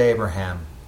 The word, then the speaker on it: Abraham: Then God said to Abraham, got to put that extra syllable in there now, as for your wife, you must no longer call her Sarai.